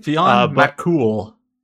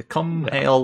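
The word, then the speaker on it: Fionn mac Cumhaill: It looks like Fionn Mac, but it's not pronounced that way. – It's like Fionn McCool. Like the cum hail looks, it's just like cool. I don't know. It's not cum hail. That's not how you pronounce it.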